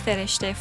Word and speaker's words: Fereshteh: Yes, my name is Fereshteh Furu.